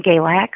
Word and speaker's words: Galax: Galax. How do you say it? – Right. In Galax. – In Galax, mm-hmm. Yes.